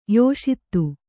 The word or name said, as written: ಯಶೋದೆ